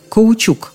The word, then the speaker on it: каучук: Ну, а пока идет примерка, есть время поговорить о слове «каучук», которое вот именно так и произносится – «каучук». Для желающих произнести его иначе в словарях есть специальная помета «неправильно».